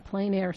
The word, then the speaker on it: plein air: It's Raton Plein Air Society. – We're mispronouncing it. It's plein air. Plein air. Because it's French.